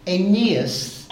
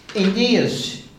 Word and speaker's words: Aeneas: And in verse 34, Peter said unto him, Aeneas, emphasis on the second syllable, I tried to get my pronunciation right, Aeneas, Jesus Christ maketh thee whole.